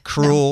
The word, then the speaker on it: Krul: Cruel. No. Cruel. K-R-U-L.